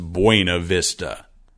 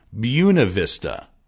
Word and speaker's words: Buena Vista: No, it's not pronounced Buena Vista. It's pronounced something like Buena Vista.